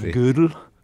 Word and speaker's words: Gödel: I hear it often pronounced, well, I don't speak German, but mathematicians often say it like the woman's piece of clothing, you know, from the old days. It's something like Gödel.